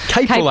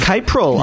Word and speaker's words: Capril: Capril, I believe. – Capril. Yes. I'm pronouncing it incorrectly.